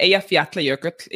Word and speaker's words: Eyjafjallajökull: So it's good, I think, to break the word down. And so if you do that, Eyjafjallajökull is how you say it together. But Eyja. – Eyja. – Like Eyja. Yeah. And then there's a double L sound that happens.